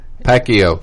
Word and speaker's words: Pacquiao: Pacquiao.